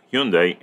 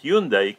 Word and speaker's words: Hyundai: Ahí lo tienen ustedes, los dos vehículos de Honda, de Hyundai, perdón, de Hyundai que tuvimos, hay gente que lo pronuncia Hyundai, Hyundai, Hyundai, yo me acostumbré a usarlo así, que es la manera como lo usamos en español, Hyundai, la marca coreana, tuvimos dos productos de ellos aquí en nuestro driveway y queríamos compartir con ustedes las impresiones de manejo.